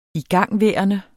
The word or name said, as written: igangværende